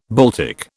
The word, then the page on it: Baltic: British English